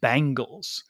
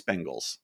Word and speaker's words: Bengals: But I hear people pronounce it Bangles. – No, it's Bengals.